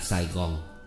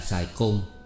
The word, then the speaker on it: Sài Gòn: Viết làm vậy nhưng đến khi đọc thì luôn luôn đọc là Sài Gòn Về sau có nhiều người đọc là Sài Côn Tưởng rằng đúng Ngờ đâu đọc như thế là phản ý người xưa